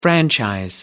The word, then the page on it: franchise: American English